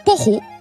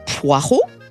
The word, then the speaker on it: poireau: Les dictionnaires considèrent « poro » comme un archaïsme. Mais vous avez toujours le choix entre « poireau », conforme au français de référence et donc légitime, et une prononciation conforme à son origine latine, ce qui est une forme de distinction aussi.